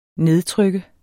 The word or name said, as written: nedtrykke